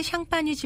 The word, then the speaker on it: Champagne: 샴페인도 떨어지고 샴페인은 프랑스의 샹파뉴 지방을 영어식으로 발음한 것인데요.